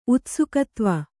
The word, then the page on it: ಉತ್ಸುಕತ್ವ: kannada